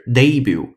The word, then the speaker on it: debut: 新会员我们来一起看一下，d e b u t，并且它的读音非常重要，debut 或者是 debut，两个发音都行。英音呢喜欢把重音放在前面，debut；美音呢喜欢把重音放在后面，debut 这样的处理。